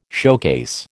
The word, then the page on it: showcase: American English